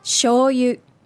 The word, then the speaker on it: shoyu: So shoyu has a little bit of an interesting pronunciation. – Mm-hmm. – And that it's a long vowel for the sho and then a short vowel for the yu.